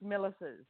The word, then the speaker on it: Milasas: It's Milasas.